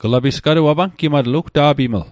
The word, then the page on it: apangimatl: Mi'gmaq